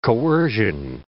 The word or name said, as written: coercion